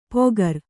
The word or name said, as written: ಪೊಗರ್